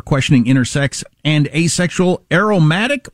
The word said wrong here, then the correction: aromantic: I'm sorry, I mispronounced that. Yes. – Is that a group now? Are they demanding the pleasant smelling are now marching and demanding their rights? – Aromantic. So non-romantic, not a romantic.